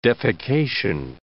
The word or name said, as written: defecation